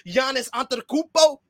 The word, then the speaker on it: Giannis Antetokounmpo: Luka Doncic, Giannis Antetokounmpo.